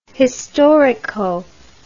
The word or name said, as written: historico